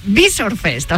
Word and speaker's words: Visor Fest: No, Yabres me dijo Visor Fest, no Visor. – Vale, Visor Fest, o sea, con la vale, con el acento en la I.